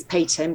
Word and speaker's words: Peyton: My website's called Peyton Principles, and my surname is Peyton, which is spelled P-E-Y-T-O-N.